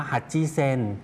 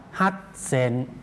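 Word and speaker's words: ฮัต เ ซ น (八千): ฮัจจิเซนแต่ออกเสียงว่าฮัตเซน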